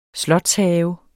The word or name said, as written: slotshave